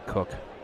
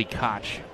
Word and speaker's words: Koch: It's K-O-C-H, but his family pronounces it not Koch, Cook. Here's a foul off the foot of Theis. And he's going to need a moment to walk that off. There used to be a reliever, you may remember. He was one of the original 100-mile-per-hour guys, Billy Koch. Spelled the same way, K-O-C-H, but he was Koch. This is Cook.